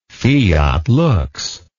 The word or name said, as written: fiat lux